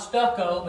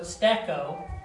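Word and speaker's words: stecco (στήκω): Not stucco, but stecco is how it's being pronounced as I listen to others pronounce it online.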